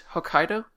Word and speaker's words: Hokkaido: So the Best Hitman Levels are Colorado, Sapienza, Paris, Marrakesh, Bangkok, and Hokkaido. Is that how you pronounce it? – Mm-hmm. It is.